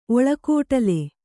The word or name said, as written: ಒಳಕೋಟಲೆ